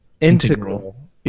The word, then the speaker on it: integral: Is it integral? – Integral. – Integral. – Correct.